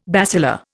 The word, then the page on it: basilar: Indian